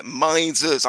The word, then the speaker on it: Mises: No, it's the Mises Caucus. That's how you pronounce it. Mises. I know Americans struggle with the English language, but, it's Mises.